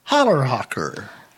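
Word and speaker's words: Challerhocker: We were having dinner the other night, and on the menu, it looked like we were going to have some Schallerhocker cheese. – Oh, yeah. – And I learned that it's actually called Hollerhocker. – Oh. – So the C is silent, see? – Yeah.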